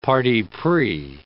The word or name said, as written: parti pris